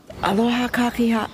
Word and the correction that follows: Aloha kakahiaka: Blah. Got to pronounce it correctly. Aloha kakahiaka.